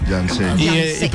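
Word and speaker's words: Janze: Con J y con Z, J-A-N-Z-E, Yancé.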